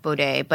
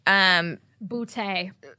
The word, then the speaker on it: Boudet: So the host of Sword and Scale is a guy by the name of, I don't know if I'm saying his last name right. His name is Mike Boudet, but it's spelled B-O-U-D-E-T. You're just mad because your memory doesn't work like mine. That's why, you guys. – It never does. – We're going to call him Mike Boudet.